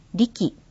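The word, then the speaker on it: RE樹: リキはアルファベットの RE に樹木の樹と書いてリキと読みます。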